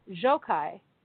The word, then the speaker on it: Gjokaj: Enver Jokaj, G-J-O-K-A-J.